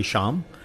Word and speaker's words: Shyam: As my family would, so it has sort of a Y in it, and it was then translated identically to be Shyam, if you pronounce it that way, but it's actually Sham from that perspective.